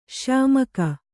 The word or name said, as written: ಶ್ಯಾಮಾಕ